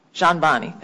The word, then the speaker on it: Jahanbani: Jahanbani, thank you. – Silent D, thank you.